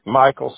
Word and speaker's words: Michael: Michael C. Sturgis. – Okay. And Michael, you spell it the traditional way? M-I-C-H-A-E-L? – Yes, ma'am.